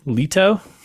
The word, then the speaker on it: Leto: So we got Jared Leto. Is that pronounced correct? – Yeah, close enough.